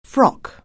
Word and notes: frock: anglais

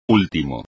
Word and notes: último: espagnol

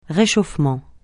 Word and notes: réchauffement: francais

